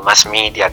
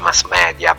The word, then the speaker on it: mass media: poi caratteristica de, degli anglosassoni che non sanno leggere il latino, ad esempio dicono mass media che è una parola latina, invece si pronuncia mass media, però vabbè, lasciamoli stare.